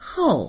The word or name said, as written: hou4